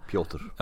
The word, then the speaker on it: Piotr: Piotr Johansson, spännande. – Piotr. – Ja, var kvar. Bra uttal där.